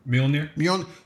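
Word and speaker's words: Mjölnir: Mjolnir.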